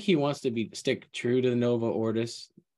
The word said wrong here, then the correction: Novus Ordo: or Norvo Ordis. How do you say Novus Ordo? – Novus Ordo.